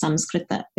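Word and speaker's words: Samskrita (संस्कृत): But I think what happens is, right, when you hear a word yoga in and of itself is Sanskrit, or as my parents say, it's Samskrita, is Sanskrit word.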